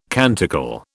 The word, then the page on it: canticle: British English